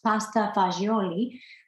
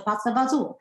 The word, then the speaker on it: pasta fagioli: She made something that is supposed to be pronounced pasta fagioli, but most New Yorkers butcher it and they pronounce it pasta fazul, or because it comes from the Sicilians and they drop all the vowels.